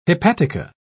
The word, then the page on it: hepatica: British English